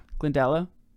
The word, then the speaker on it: Glendalough: And from Kildare, we're going to go to, on day eight, Glendalough. How do you say that? – Yeah. – Glendalo? – Yeah.